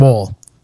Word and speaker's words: Woll: My one redhead that I chose, but also is from the TV show True Blood, which is Deborah Ann Woll, or Wall, depending on how you pronounce it.